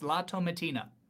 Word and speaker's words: La Tomatina: Tomatina? Yes, La Tomatina.